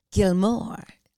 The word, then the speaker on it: Gilmour: I mean, honestly, I'm sure our listeners are thinking that we're saying Gilmore like the Gilmore Girls. – Right. – But it's spelled G-I-L-M-O-U-R. Gilmore.